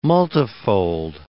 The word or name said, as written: multifold